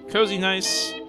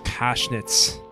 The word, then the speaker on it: Kozienice: Okay, Kozienice, the town. Oh, look at that palace. Looks cozy and nice. – It's a cool little pool they got out front there. – Aw, man. It's actually pronounced Koshnitz.